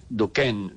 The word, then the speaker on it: Duchenne: Hay un estudio muy importante que se ha hecho que se llama La sonrisa de Duquesne Duchenne, Duquen, la pronunciación,